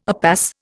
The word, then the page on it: upas: Indian